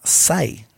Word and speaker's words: xi: Y luego hay otra letra que se llama SAI Ajá. Que se pronuncia así, Sai, S-A-I, pero se escribe X-I.